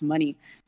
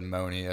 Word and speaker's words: Monie: Um, so my last name is actually pronounced money, just like cash or anything Hell like yeah.